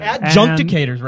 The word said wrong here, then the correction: adjudicators: right? Is this how it's pronounced? Adjunctators? – Adjudicators.